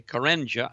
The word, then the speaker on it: Karenga: Ladies and gentlemen, here's an article that says, talking about the individual, his name is M-A-U-L-A-N-A, Malano, K-A-R-E-N-G-A, Karenja, I think would be the way you pronounce it.